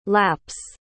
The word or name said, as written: laps